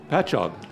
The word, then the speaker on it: Patchogue: How do you say that? – Patchogue. – Thank you.